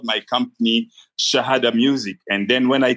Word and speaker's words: Shahada: I understand you call it Radio Shahada. Did I pronounce that correctly? – Right, sir.